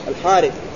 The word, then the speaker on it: الحارث: وإن كانت تكتب هكذا الحرث لكن تنطق الحارث.